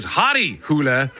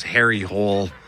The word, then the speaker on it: Harry Hole: starring a character whose name is Hari Hule, or as it's spelled, if you were to read it in English, his name is Harry Hole.